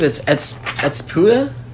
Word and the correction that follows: Ättestupa: Is that how you say it? – It's called Ättestupa. – Ättestupa.